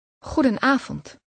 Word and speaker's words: Goedenavond: Goedenavond. Avond. Avond. Goeden. Goedenavond. Goedenavond. – Did you hear the N sound in the middle of that word?